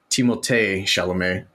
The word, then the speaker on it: Timothée Chalamet: So Timothée Chalamet, as it's actually pronounced from what he has stated in the past, Timothée Chalamet, because you got to throw that little French flair on there.